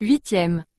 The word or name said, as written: 8e